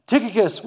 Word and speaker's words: Tychicus: Now, if you want to pronounce his name right, it goes like this Tychicus.